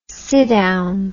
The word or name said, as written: siddown